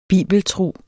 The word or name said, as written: bibeltro